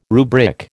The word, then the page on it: rubric: American English